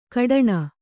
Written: ಕಟ್ಟಣಿ

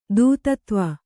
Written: ದೂತತ್ವ

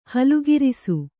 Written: ಹಲುಗಿರಿಸು

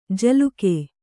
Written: ಜಲೌಕೆ

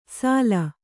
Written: ಸಾಲ

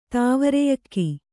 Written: ತಾವರೆಯಕ್ಕಿ

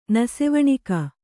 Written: ನಸೆವಣಕ

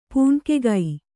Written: ಪೂಣ್ಕೆಗೈ

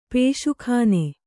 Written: ಪುಸಕ್ಕನೆ

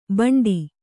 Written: ಭಾಂಡೆ

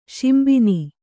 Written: ಶಿಂಬಿನಿ

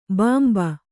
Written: ಭಂಭಾ

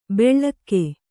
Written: ಬೆಳ್ಳಕ್ಕೆ

